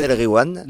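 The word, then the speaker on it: Nass El Ghiwane: Nas El Riwan. – Nas El Riwan, voilà. Et quand il y a GH, tu dis Re. Tu fais comme si c'était un R.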